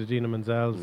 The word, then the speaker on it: Idina Menzel: Into the Unknown, they had a number of singers who did Idina Menzel's mm.